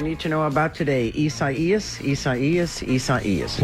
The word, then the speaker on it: Isaias: But it looks like Isaiah. – It looks like Isaias, and that's how everybody's been pronouncing it.